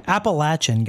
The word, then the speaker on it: Appalachian: Appalachian, guys. Appalachian. – Sorry, Appalachian Sipping Cream. – Here's how you say it. Appalachia. Appalachia.